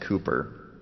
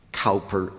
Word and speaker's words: Cowper: I know that his name is pronounced Cooper. And I know that because, once again, I get letters, particularly from the English purists, who say, "Pastor Lutzer, get it straight. It's not Cowper, it's Cooper."